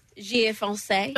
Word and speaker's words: Givenchy: G-I-V-E-N-C-H-Y. – All right. – Givenchy.